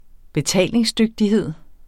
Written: betalingsdygtighed

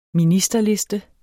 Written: ministerliste

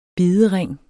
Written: bidering

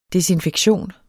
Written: desinfektion

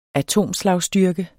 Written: atomslagstyrke

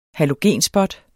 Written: halogenspot

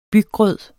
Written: byggrød

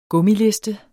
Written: gummiliste

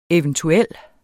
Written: eventuel

